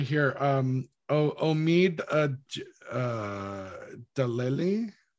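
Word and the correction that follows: Djalili: Omid Jalili.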